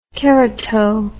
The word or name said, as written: kerato